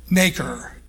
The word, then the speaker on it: nacre: The inside of the oyster shell is a shiny substance called nacre, pronounced N-A-K-E-R is the way it sounds like it because it's a long A, but it's actually spelled N-A-C-R-E, also known as mother pearl.